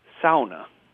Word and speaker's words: sauna: The proper pronunciation would be sauna, much like pow.